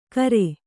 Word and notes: ಕರೆ: kare

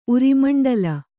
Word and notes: ಉರಿಮಂಡಲ: urimaṇḍala

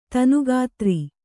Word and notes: ತನುಗಾತ್ರ: tanu gātra

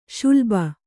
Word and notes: ಶಲಭ: śalabha

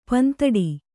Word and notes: ಪಿಂದೊಡೆ: pindoḍe

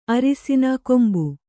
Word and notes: ಅರಿಸಿನ ಕೊಂಬು: arisina kombu

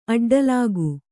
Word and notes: ಅಡ್ಡಲಾಗಿ: aḍḍalāgi

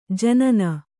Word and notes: ಜನನ: janana

